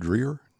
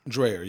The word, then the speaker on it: Dreier: This guy, is his last name pronounced Dreer? – Dreher, yeah. – Dreher, D-R-E-I-E-R, 99 years old.